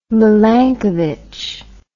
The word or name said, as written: milankovich